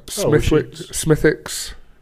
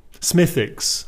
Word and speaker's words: Smithwick's: How do you say that word? Smithwick's? Smithwick's? – I'd say Smithwick. No. Smithwick's. Maybe Smithwick's. The W is like silent. It makes it easier for me to say anyway.